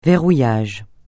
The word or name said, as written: verrouillage